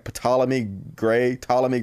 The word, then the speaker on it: Ptolemy: Ptolemy Gray. I think the P's silent.